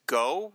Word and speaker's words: Gho: Artwork-wise, we haven't mentioned the colorist, which is Sonny, I believe it's Go, G-H-O, okay. Is how you pronounce it.